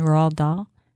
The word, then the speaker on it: Roald Dahl: Like you have, you know, when he was a boy, he read a lot of Dr. Seuss and Roald Dahl.